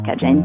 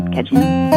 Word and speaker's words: cadien: Et en fait, il y a deux orthographes acceptées en Louisiane. Il y a l'orthographe qui tient plus à l'étymologie, qui est C-A-D-I-E-N ou I-E-N-N-E au féminin. Et puis, il y a l'orthographe qui tient plus à la prononciation, ce qui est C-A-D-J-I-N. Mais en général, ça se prononce cajun, cajun.